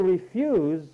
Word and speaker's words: refuse: It will refuse refuse. See, if you look up in the dictionary, there's two refuses there. Refuse, number one, and the next word is pronounced refuse.